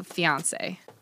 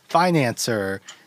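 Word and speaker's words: fiancé: Anyways, he pretty much says, he keeps mispronouncing fiance. – Yeah, he's like, "This is my financer, Elizabeth." – Fiance.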